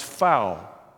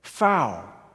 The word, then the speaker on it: Pfau: It's spelled P-F-A-U, and Eric Fenton says it's pronounced Fau.